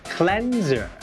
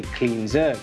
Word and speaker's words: cleanser: คำตอบที่ถูกต้องก็คือข้อ C cleanser ครับเพราะ cleanser แปลว่าผลิตภัณฑ์ทำความสะอาดผิวและต้องออกเสียงว่า cleanser ไม่ใช่ cleanser